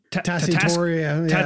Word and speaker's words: Tatasciore: Fred Tatasciore.